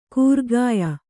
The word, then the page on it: ಕೂರ್ಗಾಯ: kūrgāya